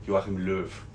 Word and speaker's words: Löw: In my opinion, like, the German national team, they need a new trainer because, like, Joachim Löw is how do you say his last name? – Joachim Löw. – I was so wrong on that.